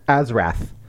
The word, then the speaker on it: Azrath: Our first sex scene, Azeroth's like, it's Azeroth, right? – Yeah. – Azrath. – Azrath. Sorry. Oh, I said that wrong.